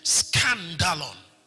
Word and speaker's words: skandalon: It is spelled S-K-A-N-D-A-L-O-N, scandalon.